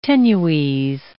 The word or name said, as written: tenues